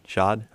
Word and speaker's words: Shad: The owner of the Jacksonville Jaguars, Shad Khan, if I'm saying that right. – Shad, okay. Yep.